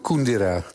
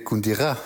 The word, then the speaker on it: Kundera: Ik noem hem Milan Kundera. Dat is niet goed, hè? Zijn achternaam zo nee, uitgesproken. – Het is eigenlijk Kundera. – Ja. – Maar in Frankrijk heet hij weer Kundera. Dus dan hebben we drie varianten.